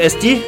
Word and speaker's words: Asti: Ja, det, men det udtales as de.